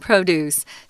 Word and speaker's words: produce: You might look at that and think it's produce, but it's not. It's a noun, produce. Sorry about that. And notice that the. Um, the, the stronger accent, the stronger syllable is on pro.